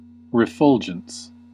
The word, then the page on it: refulgence: /ɹɪˈfʌl.dʒənts/; /ɹəˈfʌl.dʒənts/; /ɹəˈfʊl.dʒənts/